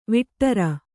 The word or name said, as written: ವಟ್ಟಾರ